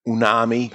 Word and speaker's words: umami: I didn't like the cold draw of that umami.